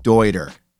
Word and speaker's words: Deuter: Dude, it's pronounced Deuter. I didn't name them, they named them. It was a man's last name, that's how it's pronounced. No more Deuter it is Deuter.